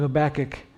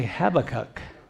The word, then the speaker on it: Habakkuk: The two most common ways of pronouncing it is the way I just said, Habakkuk. That's the way you hear it the most. It's kind of a tongue twister. Habakkuk. That's how Chuck Swindoll pronounces it, how John MacArthur pronounces it, how John Corson pronounces it. The reason I know is I listen to all these guys. And they all say Habakkuk, but not Chuck Smith.